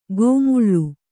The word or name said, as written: ಗೋಮುಳ್ಳು